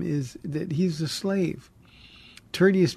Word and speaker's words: Tertius: Tertius is it's Tertius is is the pronunciation, um, and he's a remarkable story.